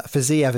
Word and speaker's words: Fiziev: Um, but uh, Fiziev is my favorite one because it sounds fizzy.